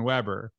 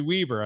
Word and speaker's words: Weber: and I just assume it's going to be Steven Weber. And I asked him before, and he's like, "It's actually Weber." I was like, "That's why, yeah." So I'm like so appreciative now because I can say your name right.